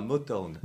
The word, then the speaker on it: Motown: Motown, prononce bien, s'il te plaît. Motown Oui, presque. Ah, vas-y, redis-nous. Motown. Motown. M-O-T-O-W-N, inspiré du fameux label euh, musical Motown.